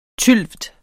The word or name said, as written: tylvt